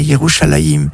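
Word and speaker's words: Yerushalayim (ירושלים): Et c'est très intéressant, parce que dans la traduction de Jérusalem, dans toutes les langues en général, on parle de Jérusalem, Jérusalem, eh bien c'est prononcé sans le Yud. Alors qu'en hébreu, c'est Yerushalayim,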